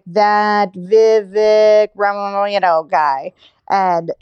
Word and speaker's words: Vivek: They like see DeSantis as like the main alternative, but their hearts were with Vivek Ramaswamy, who, what? By the way, his name is pronounced Vivake, like a heartache, Vivake.